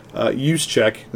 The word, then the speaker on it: Juszczyk: This last game, they actually threw a lot of passes to their fullback, Juszczyk, is how you pronounce that name.